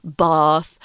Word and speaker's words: Bath: Um, because of their accent, they don't say bath like Americans. They actually pronounce it buff.